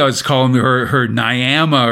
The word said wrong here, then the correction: Niamh: or whatever. No, it's pronounced Neve.